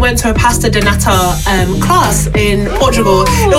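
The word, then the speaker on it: pastéis de nata: Okay, so correct me if I'm wrong. I'm probably going to pronounce this really wrong. – Yeah. – Pastries di nana? – Pasta donata.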